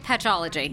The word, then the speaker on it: Patchology: Am I saying it right? Patchology. – Yes.